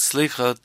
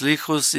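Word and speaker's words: selichot: Prayers for forgiveness, called slichot, often pronounced slichus in Yiddish, are added to the daily cycle of religious services.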